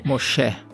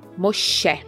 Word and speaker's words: Moshe: Eu até dei uma pesquisada e talvez Moisés venha do egípcio Mose, Moje, não sei como pronuncia. – Moshe. – Moshe.